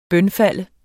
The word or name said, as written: bønfalde